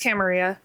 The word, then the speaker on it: Camarilla: It's like weird that Camarilla. – Okay. Tappy went on a whole thing. – It's Camarilla.